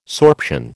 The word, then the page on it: sorption: American English